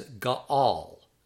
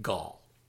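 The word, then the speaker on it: gaal (גאל): It's also possible to pronounce that as gaal, gaal, gaal. It has two syllables, but when it's read rapidly, it sounds simply like gall.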